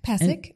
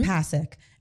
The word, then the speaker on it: Pasek: I think I'm saying his name right. Benj? – Mm-hmm. – Pasek? – Pasek. – Mm-hmm. Pasek.